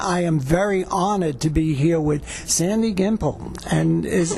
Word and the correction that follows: Gimpel: No. It's Gimpel. – Gimpel. Oh, you put the French thing on.